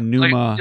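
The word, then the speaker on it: Pneuma: Yeah, for anyone out there listening, it's spelled with a P, right? – Yeah. – P-N, yeah. – Like Numa. – Like pneumonia. – Yeah. Yeah. Yeah, there you go.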